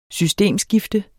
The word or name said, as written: systemskifte